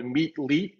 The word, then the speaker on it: Meetlete: So you have to go to your iPhone, go to the App Store, and download Meet League, which is spelled M-E-E-T-L-E-T-E. It's like Meet Athlete, Meet League.